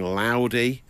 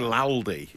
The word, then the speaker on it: Laldy: Do you mean Laldy? Yeah, so L-A-L-D-Y.